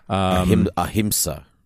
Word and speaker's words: ahimsa: And then episode four is Amisa? I'm not sure how to pronounce that. A-H-M-I-S-A. – Ahimsa. – Ahimsa, you're right. I misread that.